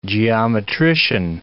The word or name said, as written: geometrician